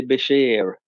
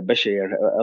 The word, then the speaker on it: Beshear: Andy Beshear, eh, eller hur man uttalar det, Beshear, alltså B-E-S-H-E-A-R ifall någon vill googla honom.